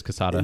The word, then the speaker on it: Quesada: Are we saying that correctly? I don't know. – Joe Quesadilla. – Quesadilla. – Quesada. I'm pretty sure it's Quesada.